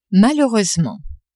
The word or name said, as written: malheureusement